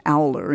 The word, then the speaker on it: alder: It was otherwise with briar, holly, or plum, which stood respectively for liar, folly, and glum, and with alder, which is pronounced owler in many country districts and so rhymes with scowler.